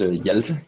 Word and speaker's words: Hjalte: H-J-A-L-T-E. And that's where it goes really wrong, because then people have no idea on how to say it. But basically, it's just Hjalte Danish.